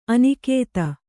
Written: ಅನುಗತಿ

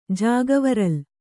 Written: ಜಾಗವರಲ್

